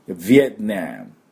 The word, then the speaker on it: Vietnam: ベトナム、ヴィエトナム、ヴィエトナム、こんな感じです。で、アクセントがですね、 2 つありまして、最初がヴィエ、次がナム。